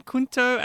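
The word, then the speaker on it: Cunto: Kunto, using the Italian pronunciation, Kunto and Othered Poems? – That's correct, yes.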